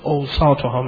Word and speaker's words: اوسطها: این ضرب المثل با همه تون به کار میبرید، به شکل ضرب المثل ولو حدیث است که میگید خیر الاموره یا اوسطها، یا اگر صحیح تر تلفظ کنید اوسطها میگید، خیر الاموره اوسطها، یعنی بهترین چیزها اون حد وسط هست.